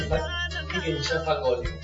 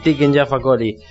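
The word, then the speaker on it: Tiken Jah Fakoly: A ver, pronunciar Taking Jaffa Coli, ¿cómo lo dirías? – Tiken Jaffa Coli. – Así, así. Tiken Jaffa Coli.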